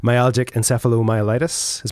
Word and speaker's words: myalgic encephalomyelitis: Myalgic encephalomyelitis, is my pronunciation correct? – Yes.